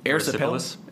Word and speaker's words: erysipelas: Erysipelas.